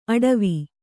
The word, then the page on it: ಅಟವಿ: Kannada